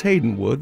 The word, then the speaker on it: Haydn Wood: We will also hear piano music by Mendelssohn, and we will hear from Haydn Wood. It's spelled like Haydn, but it's Haydn Wood, the English composer.